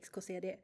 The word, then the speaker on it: XKCD: Yes, uh, when I say it, when I speak Swedish to my friends, I just say XKCD, like pronounced in Swedish, yeah.